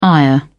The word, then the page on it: ire: British English